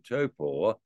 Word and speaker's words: Taupo: The Taupo track, proper pronunciation Taupo,